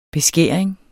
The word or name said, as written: beskæring